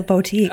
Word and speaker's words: boutique: No, you said boutique. Boo, not bow.